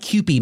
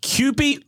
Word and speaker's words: Kewpie: I'm gonna they call it Kewpie mayonnaise. – Kewpie? Hold Kewpie. On one second. Spell it. – K-E-W-P-I-E mayo.